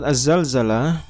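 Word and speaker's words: الزلزلة: Ini yang benar bacanya Az-Zalzalah dengan zai azza yakni alif lamnya atau alnya itu syamsiyah bukan al-Zalzalah. Karena huruf zai huruf syamsiyah.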